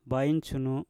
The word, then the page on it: bain sunu: mpur